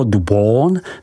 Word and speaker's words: Beaune: Côte de Beaune, vyslovko du Bon, se rozkládá od hranic obce Echevrone, vyslov Echevron, po Chagny, vyslovuj Šaní.